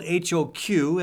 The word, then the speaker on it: HoQ: Support for this program comes from a lot of folks, including HoQ Restaurant in Des Moines East Village. That's spelled H O Q at HoQ.